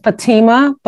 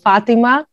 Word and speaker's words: Fatima: We pronounce it Fatima, but I love Fatima.